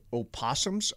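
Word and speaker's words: opossum: We've got a family of, I think they're called opossums. – Opossums. I believe the O is silent.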